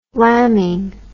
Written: lambing

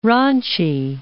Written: Ranchi

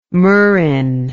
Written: murrhine

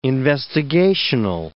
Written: investigational

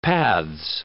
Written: paths

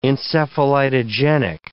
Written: encephalitogenic

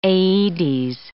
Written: aedes

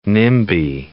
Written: NIMBY

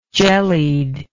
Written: jellied

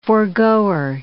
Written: foregoer

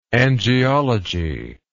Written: angiology

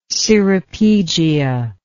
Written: ceropegia

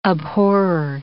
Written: abhorrer

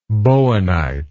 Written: bowenite

